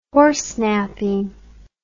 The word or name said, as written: horsenapping